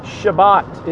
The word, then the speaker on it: Shabbat: Shabbat is the Jewish pronouncing or Hebrew pronouncing of the English word Sabbath.